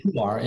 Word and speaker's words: Pu'er: I don't think I've ever had a, do you say pu'er? What is that? How you say it? Pu'er? – Yeah, I call it pu'er.